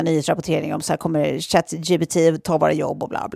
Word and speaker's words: ChatGPT: Bara det att på franska då alltså uttalar man ju det ChatGPT, vilket också låter exakt som när man som på katt. Franska säger eller? Säger katt jag pruttade. ChatGPT.